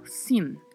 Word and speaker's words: sin: Muita atenção aí para a pronúncia dessa palavra, porque tem um N no final. Sin. Temos que pronunciar este N, porque senão fica o si do espanhol, que já tem um outro significado.